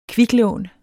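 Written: kviklån

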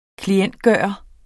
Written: klientgjort

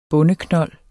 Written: bondeknold

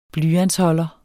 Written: blyantsholder

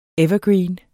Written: EVERGREENENE

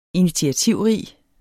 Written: initiativrig

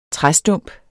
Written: træstump

